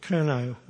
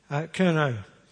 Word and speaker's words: Curnow: So it's spelt C-U-N-O. So this poem is called Curnow, without a W. Curnow.